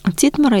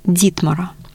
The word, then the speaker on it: Титмар: Титмара, или как его иногда произносят на современный немецкий лад, Дитмара.